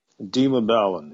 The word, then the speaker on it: Dima Ballin: Dima Ballin, yes.